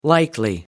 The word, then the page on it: likely: American English